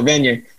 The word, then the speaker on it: Vanier: Are you Mother Teresa or Jean Vanier? – A little more emphasis on the first syllable, Vanier.